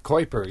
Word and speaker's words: Kuiper: Kuiper, yes.